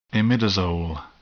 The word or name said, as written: imidazole